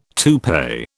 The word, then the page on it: toupee: British English